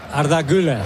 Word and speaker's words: Arda Güler: Lo digo bien, Arda Güler. – Arda Güler. – Perfecto.